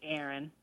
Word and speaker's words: Erin: My name's Erin. – Erin. – Spell that. – E-R-I-N.